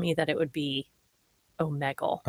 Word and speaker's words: Omegle: I'm pretty sure that's how you say it. I Googled it because I've heard people say Omegle. And according to Google, it is Omegle.